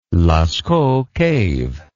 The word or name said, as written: Lascaux Cave